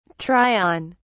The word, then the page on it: try on: British English